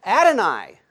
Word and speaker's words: Adonai: Sorry for that. Messed it up. Known it for years. Adonai. Yeah, they haven't changed the pronunciation.